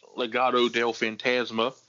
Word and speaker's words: Legado del Fantasma: Oh, Legado del Fantasma.